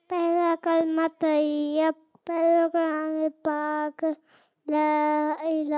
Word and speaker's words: طیب: طیب بولا کریں, طیب طیب کر رہے ہوتے ہیں, صحیح تلفظ ہے طیب.